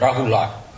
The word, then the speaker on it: Rahula: Thì họ đọc là Rahula, Rahula, thêm chữ A sau cùng.